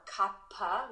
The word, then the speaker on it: Kapha: So it's K-A-P-H-A. And people typically pronounce it either Kapha or Kappa with a P, aspirated P.